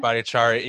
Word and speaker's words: Bhattacharya: But when I was listening to, I always butcher his last name, but Dr. J from Stanford. – J. Bhattacharya. – Yeah, there you go.